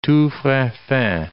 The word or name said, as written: tous frais faits